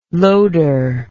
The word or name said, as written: loader